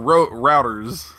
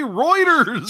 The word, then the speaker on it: Reuters: Well, Hunter, you heard about it on Rugers. – Rugers. – Yeah. – Yeah. Or routers. I just want to grab the boy by the head and go, just say Reuters.